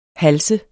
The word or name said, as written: halse